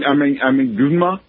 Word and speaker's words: Gunma: Yes, I'm in Gunma. – How do you spell that? – G-U-N-M-A. – Say it again. G-U... – N-M-A.